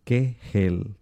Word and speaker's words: Kegel: Kegel o Kegel. Se escribe K-E-G-E-L. Kegel, literalmente, pero se pronuncia Kegel.